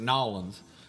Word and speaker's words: New Orleans: That's the appropriate pronunciation is New Orleans.